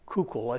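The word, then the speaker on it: Koukl: Kuchel, I think, is the correct pronunciation.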